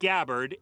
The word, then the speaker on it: Gabbard: Gabbard. – Hawaii's congresswoman pronounces it Gabbard, and her first name is Tulsi with an I at the end.